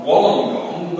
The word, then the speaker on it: Wollongong: We're all very used to it now, but it was very hard for us to get our heads around the fact that a place name is spelled W-O-L-L-O-N-G-O-N-G is pronounced not Wollongong, but Wollongong.